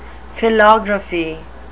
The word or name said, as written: philography